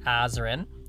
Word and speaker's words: Azren: It is at polyglot Azren, that is spelled P O L Y G L O T, A Z or Z, depending on your country, R E N.